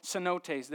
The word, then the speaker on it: cenotes: In the Yucatan Peninsula there's all these little things called cenotes.